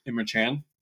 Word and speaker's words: Emre Can: but Emre Can. – It's Emre Can. – There we go.